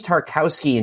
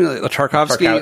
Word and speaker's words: Tarkowski: I mean, I will say, from an Everton fan's perspective, Tarkowski, I think is his name, Tarkowski. – Tarkowski, yeah. – I think they're pronouncing the W as a V, I think, during the commentary.